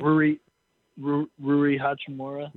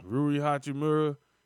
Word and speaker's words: Rui Hachimura: Rui Hachimura. – Yeah, Rui Hachimura.